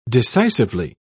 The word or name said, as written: decisively